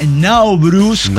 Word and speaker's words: Nowruz: Así que bueno, y hoy es el Nowruz, o el Nowruz, como se pronuncia, que es el año nuevo persa.